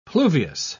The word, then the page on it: pluviose: British English